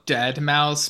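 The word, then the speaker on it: deadmau5: So According to this, it's called Dead Mouse, but it's spelled DEADMAU 5, like the number 5.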